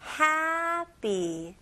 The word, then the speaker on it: happy: 근데 발음은 사실 달라요. 네, happy는 훨씬 더 짧은 모음이고요. 여기서는 A 발음을 조금 더 길게 해주셔야 돼요. 제 입모양을 보시면서 같이 한번 따라해 볼게요. happy.